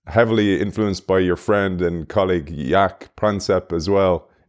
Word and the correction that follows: Panksepp: Do I say his name right there, Mark? – Panksepp, yes.